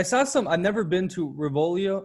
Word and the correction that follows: Rovigo: or how do you say it? – Rovigo, Rovigo.